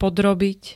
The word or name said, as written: podrobiť